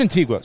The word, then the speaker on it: Antigua: Is it pronounced Antigua? – Antigua. – It is Antigua.